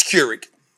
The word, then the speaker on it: Keurig: I said, I pronunciated it correctly. It's Keurig. Oh, all right, so Keurig.